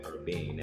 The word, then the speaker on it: Urbane: But as you think about the future of Urbane, or how do you pronounce it? – Urbane. – I'm saying it right? – Yes.